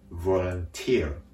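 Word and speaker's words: volunteer: 日本語ではボランティアとなるんですけれども、英語の単語ではボランティア、ボランティアと語末にアクセントがあることに注意です。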